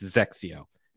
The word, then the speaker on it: XXIO: It's not anything. It's Zexio. And finally, Srixon.